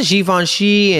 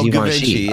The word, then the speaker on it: Givenchy: Other people call it Givenchy, but it's not Givenchy. Oh. It's not Givenchy. It's Givenchy. Givenchy? God. Dude, I don't even know who you are anymore.